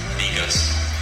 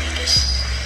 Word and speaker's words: Negus: Negus. – This is great. – Negus.